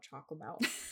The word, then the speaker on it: Chocomel: It's a can of, I'm going to pronounce it wrong, I'm sure, Chocomel? – Yeah. – Yeah, Chocomel. Yeah.